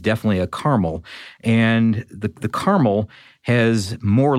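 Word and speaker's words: Carmel: This is a model called the Caramel, and it's C-A-R-M-E-L. It's caramel. It's not caramel like the candy.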